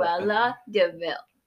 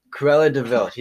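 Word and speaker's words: Cruella de Vil: Cruella DeVille. – Cruella DeVille.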